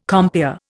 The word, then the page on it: compeer: Indian